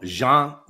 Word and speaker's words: Gian: So we've got a question from, first name, G-I-A-N. How do we pronounce it? Because it's probably short for Gianni. – Okay. So Jean Dedicoli. Does that sound right? – Sure. – C-O-L-L-I.